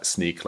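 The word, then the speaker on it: Snyk: But the right pronunciation is Sneak, like sneaking out per the origin of the word.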